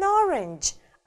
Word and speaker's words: orange: Апельсин будет an orange, а цвет просто orange. Запоминаем. Смотрим на транскрипцию, ударение тоже падает на первый слог. Orange, оранжевый, orange.